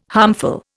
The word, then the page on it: harmful: Indian